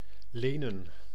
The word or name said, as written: borrow